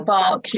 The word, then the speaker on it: Bach: five flower remedies like Bax or Bach, yeah. – I believe it's actually pronounced.